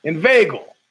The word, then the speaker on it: inveigle: Inveigle. I've never known that's the way you pronounce it. Inveigle. Maybe I should use the pronunciation key. Here we go. – Inveigle. – That's what she says, inveigle.